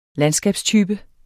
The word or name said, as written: landskabstype